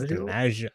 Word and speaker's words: DiMaggio: How do you say his last name? – DiMaggio. – It's DiMaggio. I think it's like Gio. Gio.